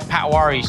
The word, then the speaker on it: Patwary: P-A-T-W-A-R-Y, Patwari. So at Shomi Patwari, that's my Instagram.